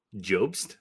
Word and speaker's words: Jobst: J-O-B-S-T. – Jobst? – Jobst.